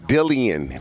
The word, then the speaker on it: Dillian: No, it's Dillian. That's how he wants it pronounced.